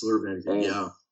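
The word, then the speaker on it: Laura Vanderkam: Yes, Laura Vanderkamp, Fuck, she's incredible.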